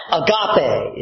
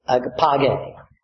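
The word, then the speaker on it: agapage (ἀγάπη): We often pronunciate it agape. It's really agapage. But that's okay. It's been transliterated to the English as agape.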